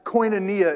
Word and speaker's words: koinonia: Last week we talked about this word koinonia, and that's koinonia is the correct pronunciation there.